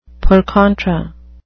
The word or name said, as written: per contra